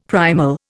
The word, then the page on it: primal: Indian